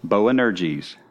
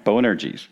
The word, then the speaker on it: Boanerges: I want you to say what I say. Boanerges. Boanerges. There you go. Now you know how to pronounce that word, all right? Boanerges.